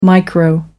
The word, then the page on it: micro: American English